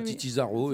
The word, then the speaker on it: Titi Zaro: Comme ça se prononce avec un Z, Titi comme Titi, T-I-T-I, Zaro, Z-A-R-O, Titi Zaro.